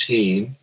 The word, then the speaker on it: Hihn: And at that time, the property was owned by a man by the name of Frederick Augustus Heen, spelled H-I-H-N, but pronounced Heen, who was quite the developer around Santa Cruz.